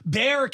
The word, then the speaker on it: Bexar: Bexar County, Texas. So the X is silent.